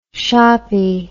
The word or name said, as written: shoppie